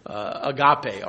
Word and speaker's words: agape: Agape, or agape as some pronounce it.